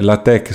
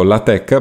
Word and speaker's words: LaTeX: anche se eh, la tech si scrive latex ehm, si legge appunto la tech proprio perché ha questa eh,